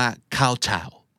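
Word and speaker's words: kowtow: ข้าวชาว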